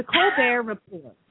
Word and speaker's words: Colbert Report: So the T is silent and he made it silent in the report too. So it's the Colbert Report.